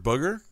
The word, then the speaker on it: booger: Oh, B-O-O-G-E-R. – So I put, it's not how I wrote it in my notes, so I've been saying bugger because it's written bad. It's bad. – Booger. It's spelled like that. Like how you'd imagine.